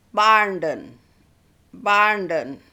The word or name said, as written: Barnden